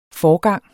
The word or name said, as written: forgang